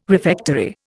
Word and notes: refectory: Indian

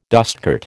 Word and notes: dustcart: American English

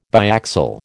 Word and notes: biaxial: American English

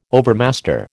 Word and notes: overmaster: American English

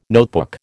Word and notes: notebook: American English

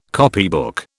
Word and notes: copybook: British English

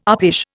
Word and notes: uppish: Indian

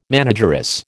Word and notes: manageress: American English

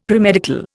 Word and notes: premedical: Indian

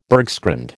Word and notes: bergschrund: American English

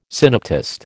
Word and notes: synoptist: American English

